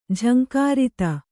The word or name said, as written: ಝಂಕೃತಿ